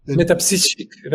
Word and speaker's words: Métapsychique: I want to encourage our viewers, particularly our viewers outside of the United States, to look into the, I don't pronounce it the way you do, the Institut Métapsychique. – Métapsychique. That's you said perfect.